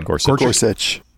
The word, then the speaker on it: Gorsuch: Because, I mean, even somebody was pointing out there was a decision from the Supreme Court, which Neil Gorsuch, I can't say his name. – Gorsuch.